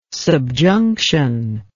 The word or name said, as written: subjunction